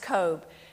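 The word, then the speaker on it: Koob: This is a picture of a German building in the back, but this is Catherine Kobe. It's pronounced Kobe, and she's one of two women and 50 men who were kidnapped during the Iranian hostage crisis.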